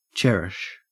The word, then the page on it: cherish: Australian English